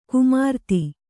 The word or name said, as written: ಕುಮಾರಿತಿ